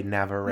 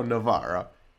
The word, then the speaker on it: Navarre: I've heard people say Navarre. It's not Navarre. I get so annoyed when people pronounce it like that. It's like, no, it's Navarre or Navarra.